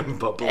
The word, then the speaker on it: Bublé: Yeah, Bubble. – And so I can't hear his name without thinking of Michael Bublé. Yeah. – I said it right. Thanks, God. – Yeah, you did.